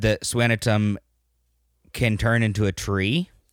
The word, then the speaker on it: Skanicum: It's spelled Scanicum, like S-K-A-N-I-C-U-M, but it's pronounced Swanatum.